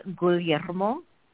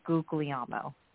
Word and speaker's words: Guglielmo: So it's Emily Guglielmo, but the American version is Guglielmo.